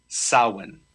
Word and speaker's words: Samhain: so I've always pronounced it Samhain, but you're saying it's Samhain? – It's in the old Celtic, it's pronounced Samhain.